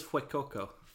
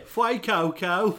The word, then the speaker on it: Fuecoco: I haven't quite landed on my pronunciation of it yet. – I thought it was Fuecoco. – I don't want to put the accent on. It sounds like I'm being disrespectful, but yeah.